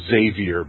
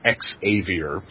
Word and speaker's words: Xavier: And then my buddy Sam Kalman, who's a professor at St. Francis Xavier, yes, it's pronounced Xavier, by the way, not Xavier, in Nova Scotia, saw the post, and he said, hey, I've got dice.